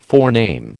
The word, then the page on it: forename: American English